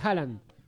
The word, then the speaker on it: Haaland: Una magia de Haaland.